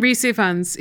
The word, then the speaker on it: Rhys Ifans: And then, is it Rhys Ifans? How do you say this guy's name? – Rhys Ifans. He's a Welsh actor.